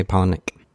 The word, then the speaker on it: Palahniuk: I say Palahniuk.